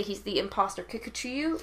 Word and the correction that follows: Kikuchiyo: I think it's Kikuchiyo.